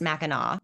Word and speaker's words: Mackinac: Yeah, so it looks like it's spelled Mackinac, people at home, but it's pronounced Mackinac. That's just how it's pronounced, Mackinac.